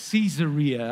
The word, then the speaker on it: Caesarea: I looked up the pronouncement on YouTube. Come on. I was saying it wrong. Caesarea, all right.